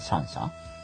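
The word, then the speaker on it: 香香: コウコウ、うん、コウコウと書いてシャンシャン。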